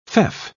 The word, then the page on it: feoff: British English